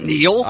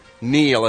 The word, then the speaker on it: Niall: N-I-A-L-L. – That's not Neil. – I don't think so either, but they're telling me that's how it's pronounced. Kneel, as if you were to kneel down in front of another member from the same band that you're in.